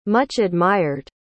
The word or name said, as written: much-admired